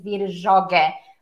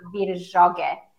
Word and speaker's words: Virjoghe: And by the way you pronounce my last name is Virjoghe, Virgilia Virjoghe. Hence, lots of people call me Vivi.